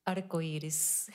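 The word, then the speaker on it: arcoíris: Arcioris? – Arcoiris. – Arcois. – Arcoiris. – Iris. Arcoiris. Arcoiris. You just pronounce every single vowel. That's how it goes.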